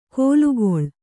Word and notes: ಕೋಲುಗೊಳ್: kōlugoḷ

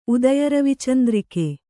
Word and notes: ಉದಯರವಿಚಂದ್ರಿಕೆ: udayaravicandrike

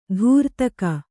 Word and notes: ದಾರಿದೆಗೆ: dāridege

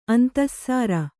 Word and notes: ಅಂತಃಸಾರ: antahsāra

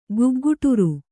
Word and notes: ಗುಗ್ಗುಟುರು: Kannada

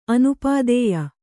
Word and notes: ಅನಪತ್ಯೆ: anapatye

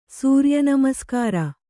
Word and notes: ಸೂರ್ಯನಮಸ್ಕಾರ: Kannada

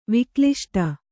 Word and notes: ವಿಕ್ಲಿಷ್ಟ: Kannada

